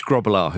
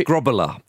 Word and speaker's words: Grobbelaar: It's Grobbelaar. it. – Actually, sorry to be pedantic, but it's probably Grobbelaar.